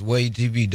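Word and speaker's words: WAYH TV: Am I saying it right? – Yes, Way TV, W-A-Y-H.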